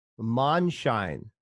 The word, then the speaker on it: Mondschein: Yes. Monshine. – Monshine. – It means moonlight.